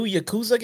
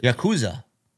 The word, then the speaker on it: Yakuza: Is that the new Yakuza game or something? – Yakuza. I'm sorry, I mispronounced it.